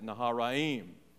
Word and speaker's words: Naharaim: And then he set out for Nahor's town of Aram the Naharaim. That's the way you pronounce that, Naharaim.